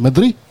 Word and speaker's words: Madrid: Donc je crois qu'on dit pas on prononce pas le D hein, en espagnol, c'est Madrid. – Ouais, c'est rapide, Madrid.